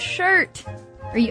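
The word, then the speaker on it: scyrte: In other words, the Old English word skirt or shirt actually refers to the word shirt. Are you following? Is everybody following? – I'm following. – Well, she says, in Old English, a shirt, pronounced shirta.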